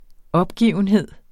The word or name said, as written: opgivenhed